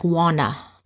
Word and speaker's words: Won: So Won, they pronounce it like Wana.